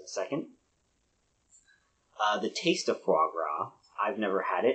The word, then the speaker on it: foie gras: Or if you pronounce it the way it's spelled, foie gras. But, you know, French, they can't pronounce things the way it looks. So, foie gras. Foie gras.